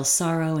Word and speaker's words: Sorro: So it's Michelle Sorrow, and that's spelled S-O-R-R-O.